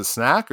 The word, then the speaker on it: Snak: Snake, I was going snack. Back and forth. Is it Snak or Snake? I guess it's Snak, right? – There's not an E on the end.